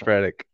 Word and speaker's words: Redick: No, you said it right. Right. It's Redick. You said it right the first time.